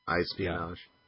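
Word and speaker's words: iSpionage: Well, it's like espionage, only with an I. iSpinage.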